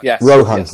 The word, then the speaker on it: Rohan: So, Rohan, am I pronouncing that right, Rohan? – Rohan.